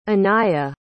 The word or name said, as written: anaiah